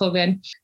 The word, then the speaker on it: Joven: And so the exhibition Tierra Blanca Joven, or Joven, sometimes my Portuguese comes out, it's pronounced Joven, which opened in April at the Brooklyn Museum.